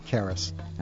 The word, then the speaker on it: Karas: Mark, I've been mispronouncing your last name. Would you please say it correctly for me now so I'm not so embarrassed? – Karras. – All right, so Mark Karras.